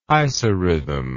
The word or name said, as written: isarithm